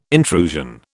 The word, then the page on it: intrusion: British English